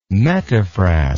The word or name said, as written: metaphrast